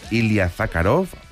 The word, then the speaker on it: Ilya Zakharov: El saltador ruso Ilya Zakharov, a ver si lo he dicho bien. – Lo has dicho muy bien.